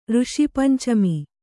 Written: ಋಷಿಪಂಚಮಿ

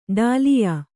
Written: ಡಾಲಿಯಾ

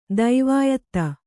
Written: ದೈವಾಯತ್ತ

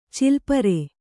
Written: ಚಿಲ್ಪರೆ

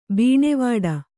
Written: ಬನಾವಟಿ

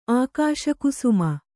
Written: ಆಕಾಶಕುಸುಮ

